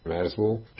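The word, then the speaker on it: Merzbow: Merzbo 1979 óta több száz felvételt jelentetett meg. A projekt neve Kultschritzer Merzbo című alkotásából ered, és a kiejtése is megegyezik azzal.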